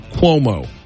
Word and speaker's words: Cuomo: Did I say his name right? Andrew Cuomo? – Cuomo. – Cuomo? – Cuomo. – Cuomo. So kind of like Cuomo here, but Cuomo.